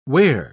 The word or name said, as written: ware